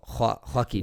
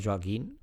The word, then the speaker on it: Joaquín: Joaquín. Joaquín Phoenix. – Joaquín, porque se escribe J-O-A-Q-U-I-N. – Joaquín. Ya, en castellano la O se pronuncia como una U, es Joaquín.